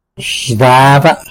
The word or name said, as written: xtada